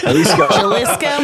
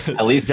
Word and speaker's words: Jalisco: Jalisco, Mexico. – Jalisco? – Jalisco.